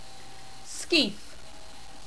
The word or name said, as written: Skeith